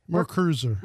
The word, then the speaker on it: MerCruiser: How do you say that? Mercury Cruiser? – MerCruiser. – MerCruiser. Yes. Okay.